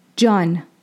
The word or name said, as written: Jān